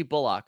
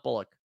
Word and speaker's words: Bullock: No, that's how you say it. It's Reggie Bullock. It's not Bullock.